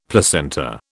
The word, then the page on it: placenta: British English